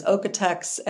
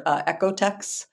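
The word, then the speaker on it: OEKO-TEX: Yeah, I think the kind of American pronunciation is Ocotex, and okay. Then sometimes in Europe you hear Ecotex.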